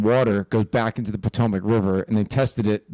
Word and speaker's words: Potomac: Potomac, yeah.